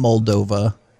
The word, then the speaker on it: Moldova: I might have butchered that. – I'm Moldova. – Nope, we can. – You didn't butcher it. Nope. – Nailed it. – No, you nailed it, bro.